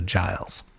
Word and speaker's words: Giles: I think they're saying it's a soft G. So Giles. – It's got to be Giles.